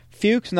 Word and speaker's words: Fuchs: Our last segment of the show, a little scamming scammers with a foreign exchange scammer, although not business enough for Joe's liking, Carolyn Fuchs, not Fuck. She doesn't like that. It's Fuchs, kids. Say it right.